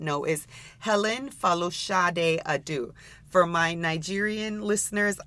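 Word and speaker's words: Folasade: And if you also listen carefully in that name, if you heard the pronunciation in her middle name, it's Falushade, and that is how her artist name came to be by taking out the Shade in Falushade.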